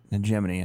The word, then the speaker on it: hegemony: How do you say that word? Hegemony. – They're like nomads. – Hegemony. I had to go to Google and have it played.